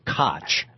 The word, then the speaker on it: Koch: And Koch is spelled, you know, the old mayor of New York used to pronounce it Koch, K-O-C-H.